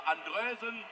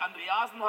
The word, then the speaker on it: Andreasen: Andrösen, die dickste Möglichkeit, unterkante Latte durch den dänischen Nationalspieler Andrösen, der nicht Andreasen heißt, nicht Andreasen heißt und auch nicht Andresen, er heißt Andrösen.